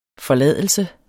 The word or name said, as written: forladelse